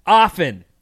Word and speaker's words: often: And I actually think often is the correct pronunciation. – I hear it like that, too, so... – When you say it, do you say often? – I go back and forth. – Well, you know what I thought of saying it wrong half the time. – Was the song by The Weeknd, Often, and he puts a T in it.